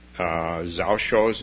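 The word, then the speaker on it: Zhaozhou: and it's Zhao Shou's dog, Joshu's dog, depending on whether you use the Chinese or the Japanese pronunciation.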